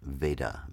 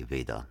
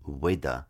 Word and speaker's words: Veda: Let's start with the word Veda, V-E-D-A, Veda. Sometimes it's pronounced in India as Veda because Indian people make that V sound a little bit like a W. But for Western purposes, let's stick with Veda.